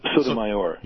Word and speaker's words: Sotomayor: Sotomayor.